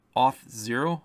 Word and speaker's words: Auth0: Well, those are both separated, into two different entities, the one being Auth0, I believe is how it's pronounced.